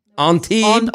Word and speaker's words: Antibes: It's going to be Antibes. Is that how you say it? – Antibes. No S? – Antibes.